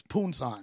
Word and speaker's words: Pusan: But Poonsan, you probably saying it wrong. It's Poonsan. – Oh, okay. – P-U-S-A-N.